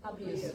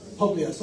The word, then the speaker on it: Publius: Publius. – Publius, okay, I'm going with what you said, okay.